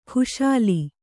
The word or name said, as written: ಖುಶಾಲಿ